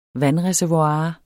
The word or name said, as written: Vandreservoir